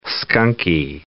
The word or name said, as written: skunky